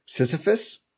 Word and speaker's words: Sisyphus: It's like some weird form of the Sisyphus? Oh, my God. My list. I can't say it. Sisyphus? – Yeah, there you go.